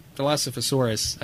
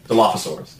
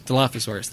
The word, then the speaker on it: Dilophosaurus: Uh, I like the Delos the Dilophosaurus. I'm not saying that right. – Dilophosaurus. – Dilophosaurus. Thank you.